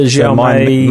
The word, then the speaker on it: Xiaomi: So have you seen the Xiaomi, is that how you pronounce it, the Xiaomi? – Yeah, the Xiaomi My Mix, I think it is.